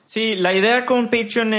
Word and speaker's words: Patreon: para, o pues, eh, por si lo dije mal, Patreon o Patreon. Es Patreon.